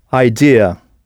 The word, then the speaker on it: idea: Et maintenant, comment direz-vous « c'est probablement une bonne idée » Alors, à l'écrit, le mot pour « idée » ressemble beaucoup au français, mais la prononciation est très différente. « Idea ». I-D-E-A.